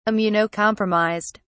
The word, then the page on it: immunocompromised: British English